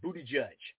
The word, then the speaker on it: Buttigieg: I mean, dude, his name is spelled B-U-T-T-I-G-I-E-G. Okay, how is that Buttigieg? It's Buttigieg.